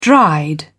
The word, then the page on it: dried: British English